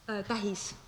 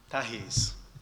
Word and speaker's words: tähis: How do you say the sign? – Which sign? – Sign between you and me. – Tähis. – Tähis. Okay.